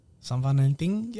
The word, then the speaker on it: San Valentín: It is San Valentín, Valentine's Day. – Is that how you say it? – San Valentín, yeah.